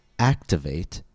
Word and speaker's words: activate: For words ending in A-T-E, stress the syllable that comes two syllables before the suffix. So for example, activate has three syllables. Activate. Count back two syllables from the suffix, and we find the stress on A. Investigate has four syllables. Investigate. Count back two syllables from the suffix A-T-E, and we find the stress on ve.